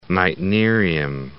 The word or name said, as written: meitnerium